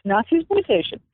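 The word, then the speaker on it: Nazisploitation: I'm not sure if I'm pronouncing it right. Is it Nazi-sploitation? – Yes, Nazi-sploitation.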